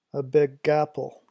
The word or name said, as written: a big apple